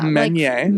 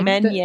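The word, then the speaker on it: Menier: Is that how it's pronounced? – Yeah. – Meunier. – Like, Meunier. Like French.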